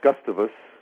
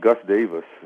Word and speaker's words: Gustavus: We got off the plane at Gustavus, or actually it's pronounced Gus Davis, you know, which is just kind of weird.